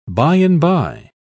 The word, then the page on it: by-and-by: British English